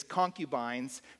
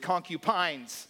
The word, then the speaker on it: concubines: It is concubines. For whatever reason, usually what comes out of my mouth, I just did it correctly, is concupines.